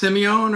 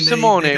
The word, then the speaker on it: Simone: I guess is it pronounced Simeone or Simone? I mean, Simone, Marco Simone. – Yeah, Marco Simone.